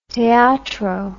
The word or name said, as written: teatro